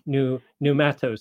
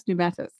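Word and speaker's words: Pneumatos (πνεύματος): Hagios pneumatikos. Okay. Pneumatos, sorry. – Hagios pneumatos.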